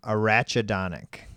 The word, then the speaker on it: arachidonic: Okay, how do you say it? – Arachidonic. – Arachidonic. – Sounds like a ratchet.